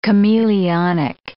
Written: chameleonic

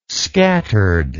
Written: scattered

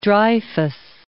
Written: Dreyfus

